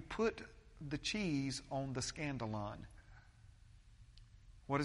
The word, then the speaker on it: skandalon: The word offense in the original language, in the original Greek language, is the Greek word skandalon. It's spelled with a K, S-K-A-N-D-A-L-O-N, skandalon.